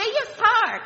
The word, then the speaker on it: yatsar: The word form in Hebrew is yatsar. Yatsar. Say Yatsar. Come on, guys. Yatsar. Yatsar. Y-A-T-S-A-R.